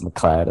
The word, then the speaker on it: MacLeod: My last name's MacLeod.